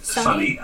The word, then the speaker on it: Suni: Was it Suni? – Sunny. Although it's spelled Suni, but Sunny had, this is a slice of life story.